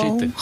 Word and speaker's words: Brigitte: Hallo, Brigitte. Goedenavond. – Brigitte, hè, op de Frans uitsprekend Brigitte.